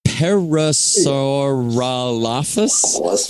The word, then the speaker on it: Parasaurolophus: but it's Parasaurolophus.